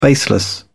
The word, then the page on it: baseless: British English